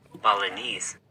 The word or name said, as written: Balinese